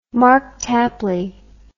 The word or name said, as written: Mark Tapley